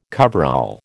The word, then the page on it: coverall: American English